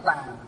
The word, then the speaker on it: tàng: Chữ tạng ở đây còn đọc là tàng, tức là cái kho công đức đó.